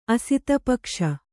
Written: ಅಸಿತಪಕ್ಷ

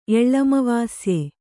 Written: ಎಳ್ಳಮವಾಸ್ಯೆ

